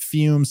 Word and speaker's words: Fum: We're talking about our sponsor, Fume, pronounced Fume, spelled F-U-M.